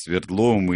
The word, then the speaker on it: Свердлов: Ездил Голощекин в Москву, принято решение было именно в столице с Свердловым и Лениным. Кстати, Олесе скажу, что давно выяснили, что ударение Свердлов.